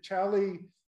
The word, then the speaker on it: Xali: So, uh, again, I invite you, Chali is how you say it, but it's spelled X A L I.